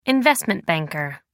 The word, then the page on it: investment banker: American English